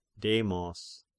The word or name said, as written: Demos